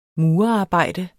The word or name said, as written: murerarbejde